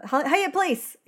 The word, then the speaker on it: Hyatt Place: I'm the one having cocktails at the Hyatt Plaza. Hyatt Place!